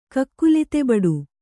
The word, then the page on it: ಕಕ್ಕುಲತೆಬಡು: kakkulatebaḍu